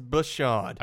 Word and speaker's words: Bashaud: It's Bashaud. – Are you sure you didn't just misspell it? I think it's Bashaud Breeland.